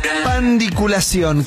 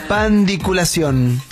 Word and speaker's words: pandiculación: Pandiculación. ¿Qué es la microson? – ¿Qué es la pandiculación? Con acento en la O final. Es la única O, ¿no? – Es como los bichos en el estómago.